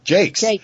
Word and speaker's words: Jacques: Okay, so we're on with Pat Jacques? – Jakes. – Pat mm-hmm. Jakes. – I was totally pronouncing it Jacques. – Well, that is, it's got the French spelling.